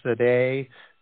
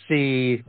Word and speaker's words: Sidhe: And if you look at a picture, if people were to Google it, it's called The Riders of the Side or the Sea or the Shea. It's spelled S-I-D-H-E.